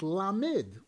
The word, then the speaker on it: Lamed: But we don't pronounce it La, we call it Lamed, which means it's as if it were Lamed Mem Dalid, right?